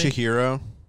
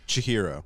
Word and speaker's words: Chihiro: Like, Chihiro. Can someone pronounce that goddamn name for me? – Chihiro. – What's that? – Chihiro. – Chihiro, thank you.